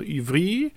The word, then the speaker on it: Ivry: Muss ja französisch ausgesprochen werden. Also Ivry.